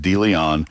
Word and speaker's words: DeLeon: D-E capital L-E-O-N. And yes, it is pronounced DeLeon.